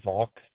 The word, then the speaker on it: Volk: Last name is going to be Volk.